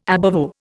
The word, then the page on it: ab ovo: Indian